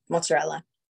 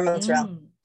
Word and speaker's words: mozzarella: I love prosciutto or prosciutto on like a burrata and then like, you know, mozzarella or mozzarella, depending on which. Staten Islanders used to be really mad at me if I pronounced that last A on mozzarella.